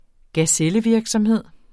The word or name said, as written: gazellevirksomhed